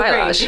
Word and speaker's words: balayage: It's so balayage. – Great. But balayage is, you know, how you say it. And basically balayage is a word. It's a French word.